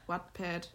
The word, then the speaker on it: Wattpad: Und ähm, da hatte ich dann ganz lange Zeit Wetpad. Kennst du das? Wetpad? Also, also W-A-T-T, also What, What Wattpad, Wattpad ja, spricht man nee, so keine aus.